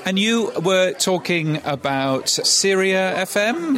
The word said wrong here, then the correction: Serie FM: Have I pronounced that right? – No, it's Serie. Yeah, Serie FM.